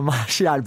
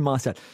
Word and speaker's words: Martial: Julien, is Anthony Martial doing enough at Manchester United? And am I saying that right? – It's Martial and not Martial. But you're not the only one. A lot of people say Martial.